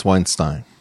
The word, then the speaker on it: Weinstein: Is it Weinstein? How do you say your last name? – I think it's Weinstein. – Weinstein. I agree. That's the more Germanic way to go about it.